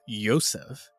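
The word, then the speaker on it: Yosef (יוסף): She says, although the name Joseph is pronounced Yosef in modern Israeli Hebrew, it wasn't always so.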